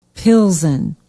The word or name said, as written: pilsen